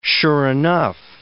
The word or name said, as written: sure-enough